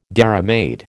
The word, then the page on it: dairymaid: American English